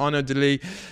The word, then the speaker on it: Arnaud De Lie: It's a French name. He's from Wallonia, so I think I can pronounce it in French, Renard. Arnaud Delis, Lee spelled L-I-E, so literally Arnaud of Lies.